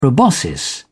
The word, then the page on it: proboscis: English; British English